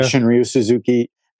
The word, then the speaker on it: Shunryu Suzuki: But Shunryu Suzuki, is that how you say right. – his name? – Yeah. – Shunryu Suzuki.